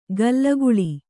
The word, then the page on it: ಗಲ್ಲಗುಳಿ: galla guḷi